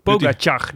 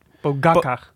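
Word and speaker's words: Pogačar: Ik was naar Eurosport aan het kijken en daar legden ze de klemtoon op de ga. Waarom was je nou... Poga Pogacar. – Pogacar. – Pogacar.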